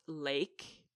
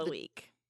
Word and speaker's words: Leek: It's a vegetable here in Canada called a leek. – Yeah. But the double E is an A sound in Dutch.